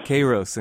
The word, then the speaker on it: Kairos: I'm talking about Kairos, is that pronounced correctly? And Chronos? – Yeah, Kairos and Chronos.